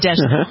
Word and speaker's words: Deschanel: The various people who are playing it, Zoe Deschanel. Is that how you pronounce the name? Deschanel? – Zoe Deschanel.